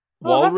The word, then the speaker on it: walrus: How are you pronouncing that word there? – Warris? – Walrus? Oh, that's interesting. – Do you say it's walrus? – Walrus. Yeah, it's not that I say walrus. That's how it's said.